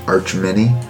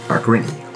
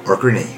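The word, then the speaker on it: Achreny: Archmini.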